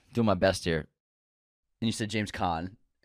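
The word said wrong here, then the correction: Caan: It's Can.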